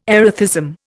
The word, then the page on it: erethism: Indian